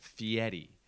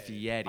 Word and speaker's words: Fieri: Say his name right. Fieri. Respect the man. Fieri. – Oh. – Fieri. – I can't even do that. – It's like an Italian name, I think.